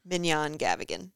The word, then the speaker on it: Mignonne Gavigan: Oh, so it's spelled M I G N O N N E. Gavigan, G A V is in Victor, I G A N. And say it. Mignonne Gavigan.